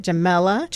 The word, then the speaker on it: Jamela: Jamela.